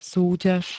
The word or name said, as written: súťaž